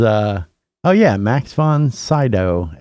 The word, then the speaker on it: Max von Sydow: No, he was, Max von Sydow, S-Y-D-O.